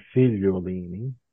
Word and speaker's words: Figliolini: The American pronunciation is works fine. – Okay. What's the Italian pronunciation? Is that? – It would be more like Figliolini.